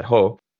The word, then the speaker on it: JOH: Before the election, I would have said that it was a major blunder because it put Ho, as he's known, J-O-H are his initials, and in Spanish you would pronounce that Ho.